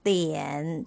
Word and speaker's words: 地: The word ti has d as a consonant. It sounded as the and with the vowel i pronounced as e sound. So we put the fourth tone on top of i and it's pronounced this way. The, e, ti, ti, ti, ti, fourth tone, ti.